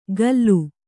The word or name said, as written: ಗಲ್ಲು